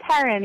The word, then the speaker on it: Heron: It's Heron, yes.